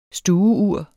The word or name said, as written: stueur